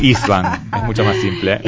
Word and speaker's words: Istvansch: Está con nosotros entonces Itz Bansch, así se pronuncia, pronunciación Itz Bansch.